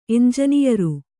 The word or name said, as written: ಎಂಜನಿಯರು